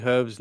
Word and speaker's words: Hryb: Um, uh, Anakin uh, JMT says Larry Hryb's name, according to him, is pronounced like Herb with the H sound, not with a silent H like the Resident Evil item.